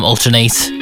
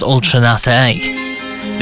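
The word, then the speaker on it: Ultra Naté: I used to call them Alternate, but it's pronounced Ultranate.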